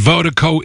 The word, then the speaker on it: Virdiko: And Vertigo is spelled V-I-R-D-I-K-O. Pronounced Vertigo, that's V-I-R-D-I-K-O.